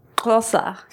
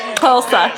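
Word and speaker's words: Xhosa: The language is pronounced Kosa. You have to do a click. Kosa.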